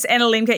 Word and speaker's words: Anna Lemke: I've heard a quote that was a great quote that you you just said, and I saw actually you reference, I'm going to say Anna Lemke. I'm not sure. – Yes, Anna Lemke.